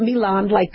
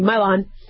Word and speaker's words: Mylan: And Mylan, if I'm pronouncing that right, it's not Milan, like in Italy, Mylan, did not really provide any details on what the quote-unquote foreign substance was.